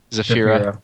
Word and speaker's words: Zafira: If it's going to be like a Delta based model, you could take, you know, the Zarifa. Is that how you say it? – Zafira. – Zarifa. I'm sorry.